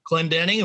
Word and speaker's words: Clendening: Am I pronouncing that right? – Clendening. It was close.